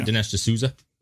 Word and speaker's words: Dinesh D'Souza: D'Souza. How do you say it? Dinesh D'Souza? – Yeah, sure.